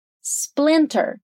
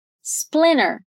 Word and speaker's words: splinter: É comum também pronunciar essa palavra quase sem esse T, né? Com um T muito, muito suave. Vai ficar splinter. As duas pronúncias são comuns no inglês americano, então você pode escolher qual você se sente mais confortável.